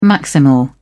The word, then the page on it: maximal: British English